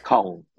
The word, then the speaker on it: Kong: Kong, yeah, Kong. Yes, like Hong Kong. Kong, the same.